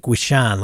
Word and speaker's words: Wishon: pronounced Wish, like Wishon, like Fishon.